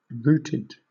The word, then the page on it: routed: British English